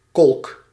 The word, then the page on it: étang: /e.tɑ̃/